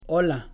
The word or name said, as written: hola